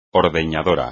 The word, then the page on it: ordeñadora: espagnol